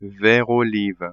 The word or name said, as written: vert olive